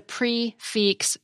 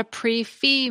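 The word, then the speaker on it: prix fixe: So a complete meal at a restaurant that's served at a fixed price is a prix-fixe meal, because pre is P-R-I-X, while fixe is spelled F-I-X-E. It's not a pre-fee meal, as I've heard some servers call it.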